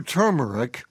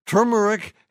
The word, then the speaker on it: turmeric: I think you did okay on that one, but when it came to turmeric, which you stated you knew how to pronounce, you dropped the pronunciation ball. Turmeric has two R's and is pronounced turmeric, not turmeric.